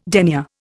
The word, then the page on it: denier: Indian